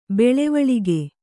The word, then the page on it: ಬೆಳವಳಿಗೆ: beḷavaḷige